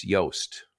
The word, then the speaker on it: Yoast: My people use Yoast, which is like Toast, but it's spelled with a Y instead of a T at the beginning.